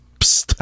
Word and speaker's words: Psst: Psst. – How do you spell that? – P-S-S-T.